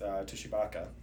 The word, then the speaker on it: Tshibaka: Because she advanced from the primary along with Tshibaka. – I believe the T is silent.